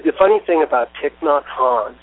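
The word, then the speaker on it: Thích Nhất Hạnh: Yeah, because I know that, you know, you have, and I'm not certain how to pronounce it correctly, but Thich Nhat Hanh. – Yes, Thich Nhat Hanh.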